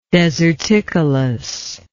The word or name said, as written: deserticolous